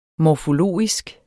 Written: morfologisk

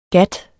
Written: gat

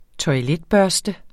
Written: toiletbørste

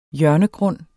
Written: hjørnegrund

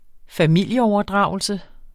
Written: familieoverdragelse

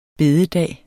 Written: bededag

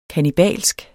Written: kannibalsk

